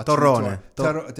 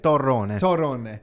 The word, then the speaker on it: torrone: Tarone. – Is that how you say it? – Torrone.